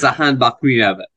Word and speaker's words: Sahan Bakmiwewa: It's Sahan Bakmiwewa.